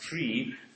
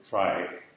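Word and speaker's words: trie: A try, and here starts the difficulty: how to pronounce this word. The thing was coined to resemble the word retrieval, and so it's supposed to be pronounced tree, but of course that leads to endless confusion if you pronounce that word tree in a data structure lesson, so I'll pronounce it try.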